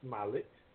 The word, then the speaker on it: Smollett: Is that how you pronounce it? Smollett? Smollett? – Smollett. Yeah. – Is it? – Yeah. – Is it Jussie Smollett? Smollett? I don't know. – It's Jussie. Jussie Smollett.